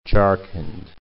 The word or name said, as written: Jharkhand